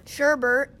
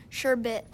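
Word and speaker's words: sherbet: And they shared, like, for example, that the word sherbert is actually pronounced sherbit.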